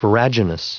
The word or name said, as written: viraginous